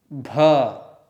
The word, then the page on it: bh: sanskrit